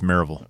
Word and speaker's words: Maryville: I believe it's Maryville. – It's Maryville. – The way it's pronounced.